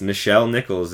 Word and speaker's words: Nichelle Nichols: but I believe it's Nichelle Nichols is how you pronounce it.